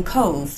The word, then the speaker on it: Cobh: And Cove is spelt C-O-B-H, pronounced Cove, C-O-V-E, but spelt C-O-B-H.